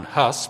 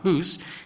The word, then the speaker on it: Hus: Some pronounce it Jan or John Hus, but it's Jan Hus.